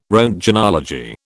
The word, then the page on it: roentgenology: British English